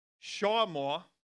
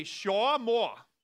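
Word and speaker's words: shawmoa (שמר): But actually the word keep is, let's see, how do you say this word? It's shawmoa. Say it. Say shawmoa.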